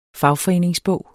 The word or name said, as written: fagforeningsbog